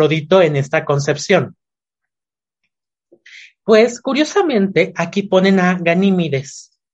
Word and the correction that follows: Ganímedes: Ganímedes, perdón, lo pronuncié mal.